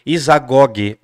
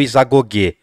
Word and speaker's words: Isagoge: Ele vai ter uma obra chamada, vocês vão poder, eh, vocês vão encontrar provavelmente como Isagoge ou Isagogue. É difícil pronunciar. Então eu vou deixar esses dois pontos aí, tá bom? Isagogue.